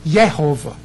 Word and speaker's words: Jehovah: Because remember, actually it's pronounced Yehovah, okay, because, you know, they didn't call him Joshua, they said Yeshua. That was Joshua's name. So J is typically a Y in Hebrew.